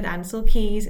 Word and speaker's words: Ancel Keys: the whole situation with Ancel Keys.